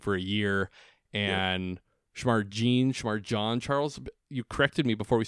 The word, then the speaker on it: Jean Charles: It's Shamar John Charles. – But it's spelled Jean Charles. – But it's spelled J-E-A-N, like Billy Jean.